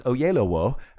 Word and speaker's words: Oyelowo: It's pronounced Oyelowo. I always remind myself that it sounds like O Yellow Wolf without the F.